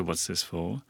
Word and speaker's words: What's This For: The emphasis, whether it's What's This For or What's This For, and I think in the album title, the word this is in capital letters. – It is that right? – Yeah. – So clearly directing us towards a particular pronunciation.